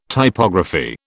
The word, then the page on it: Typography: British English